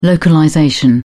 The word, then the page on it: localization: British English